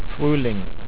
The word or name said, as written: fruehling